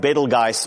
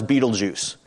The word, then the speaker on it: Betelgeuse: Anyway, you would say, if you were pronouncing it in German, it would be something like Betelgeuse, but in English we call it, this star is Betelgeuse.